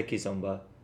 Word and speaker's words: Kizomba: So we say Kizomba. Can you spell that? K-I-Z? – Yeah. K-I-Z-O-M-B-A. – Cool. Kizomba.